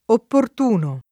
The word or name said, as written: opportuno